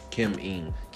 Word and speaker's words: Kim Ng: Kim Ng. – Congratulations to Kim Ng.